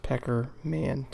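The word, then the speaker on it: Pekerman: I believe his name is pronounced Peckerman, Thomas.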